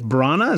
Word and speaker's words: Branagh: Now that I looked this guy up, Kenneth Branagh, is that how you pronounce his name? So, yep.